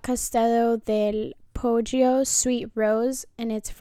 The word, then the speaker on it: Poggio: It's spelled P-O-G-G-I-O. We want to make Poggio.